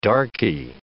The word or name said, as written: darkie